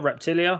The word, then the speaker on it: Reptilia: Is it Reptilia? Reptilia? Is that how you say it? – Yep.